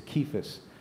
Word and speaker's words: Cephas: I'm of Cephas. I know our translations have it with a C, but it's actually a hard C. It's Cephas, which is Aramaic for rock.